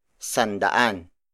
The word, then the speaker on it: sandaan: And then finally, for 100, it's sandaan.